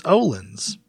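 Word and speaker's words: Öhlins: Ohlins. – Yeah, it's not hard. – Yeah, with Ohlins, just relax. It's Olin's.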